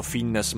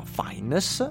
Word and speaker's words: Fiennes: L'ho sempre chiamato Fiennes, ma ho scoperto di recente che si chiama Fiennes, probabilmente perché il cognome è di origini del Galles, perché altrimenti è una pronuncia che non si spiega.